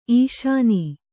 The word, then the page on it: ಈಷಣ: īṣaṇa